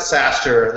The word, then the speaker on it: SaaStr: Boy, well, you, you, you do pronounce it SaaStr. That's right.